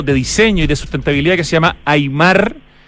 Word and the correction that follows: Aymae: A-Y-M, perdón, Aymae, ¿no es cierto? – Aymae. – Otra vez dije Aymar, lo tengo en la eso que ni siquiera me gusta el fútbol, pero bueno, Aymae, sí.